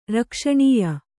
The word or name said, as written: ರಕ್ಷಣೀಯ